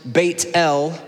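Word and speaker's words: Beit El (בית אל): Bethel in Hebrew is pronounced Beit El, which means house of God.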